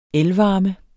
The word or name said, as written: elvarme